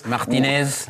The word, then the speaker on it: Martinez: como é que se pronuncia em francês Sim. o Martinez? Martinez.